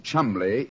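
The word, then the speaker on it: Cholmondeley: And being an American, he had no reason to know that the name Chumley is in no way pronounced the way it is spelt. – But you never thought of that. Chumley. That name spelt C-H-O-L-C-H-O-L-M-O-N-M-O-N-D-E-D-E-R-L-E-Y.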